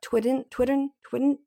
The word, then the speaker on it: Tweeden: We haven't brought up just yet Leanne Tweeden. – Tween. Tween rhymes with Sweden, I think.